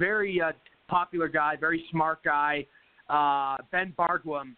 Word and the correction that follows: Bergquam: and um, so Ben Bergquam is how you pronounce it.